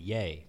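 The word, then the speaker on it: Ye: He said yee. – Yee. – Y-E, yay.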